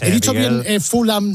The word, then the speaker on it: Fulham: Fulham, bien, Fulham.